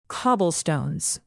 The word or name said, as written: cobblestones